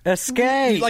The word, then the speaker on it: escape: Escape. – Like you just did. – I said escape. There's no X in escape.